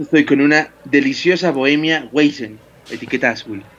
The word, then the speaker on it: Weizen: creo, que, creo que se pronuncia Weizen o algo así, no sé. – Pero bueno. Weizen, claro, porque es alemana.